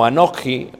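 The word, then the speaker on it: anoji (אנכי): Yo es una palabra hebrea que indica o se pronuncia como anoji o anoji.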